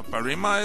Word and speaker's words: Parima: Parima. Parima is the pronunciation in English.